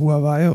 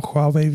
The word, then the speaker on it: Huawei: Huawei ist eine, die native, also die originale Aussprache. Ja, aber ich glaube, in Europa sagt keiner Huawei, sondern alle nur Huawei.